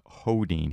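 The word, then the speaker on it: Sjödin: Sjodin, but pronounced Hodin.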